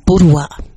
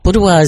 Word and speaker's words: Buduwa: It's I'm taking it from the people of the Sydney region, the Gadigal mob, and it says Buduwa. Buduwa is spelled B-U-D-U-W-A, and Buduwa means to hold one's hands by the fire and squeeze gently the fingers of another.